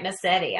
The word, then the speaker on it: Mascitti: Am I saying that right, Massetti? – Yes, you are.